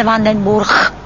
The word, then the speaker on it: Vandenburg: Například G se čte jako H, takže jméno Vandenburg se v holandštině čte Vandenburg.